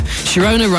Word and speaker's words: Sharona: Sharona rhymes with Corona, which is both a type of pop and also the name of the band responsible for the rhythm of the night.